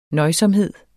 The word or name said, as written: nøjsomhed